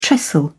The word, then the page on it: trestle: British English